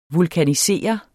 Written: vulkanisere

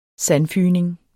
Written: sandfygning

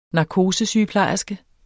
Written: narkosesygeplejerske

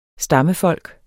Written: stammefolk

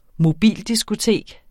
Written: mobildiskotek

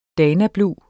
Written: danablu

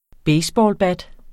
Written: baseballbat

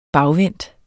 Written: bagvendt

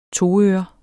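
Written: toører

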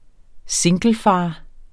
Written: singlefar

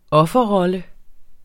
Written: offerrolle

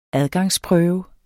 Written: adgangsprøve

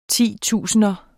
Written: titusinder

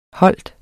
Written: holder

